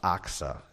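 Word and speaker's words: Achsah: Caleb said, he who attacks Kiriath Sepher and captures it, I will give him Aksah, my daughter, for a wife. Okay? That's how it's pronounced, Aksah. It's not O-X, though, so that's good, all right? Aksah.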